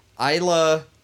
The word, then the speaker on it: Isla: Yeah, he discovered that on the 15th of November in 1528, and he dubbed it Isla Medanos? – Isla. – Isla? – Yeah. – You pronounce the S. – Yeah, dude. – Well, because there's another French pronunciation, and you don't.